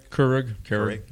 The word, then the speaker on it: Keurig: This is a little bit like Keurig coffee. – Yeah. – Am I pronouncing that right? – Keurig.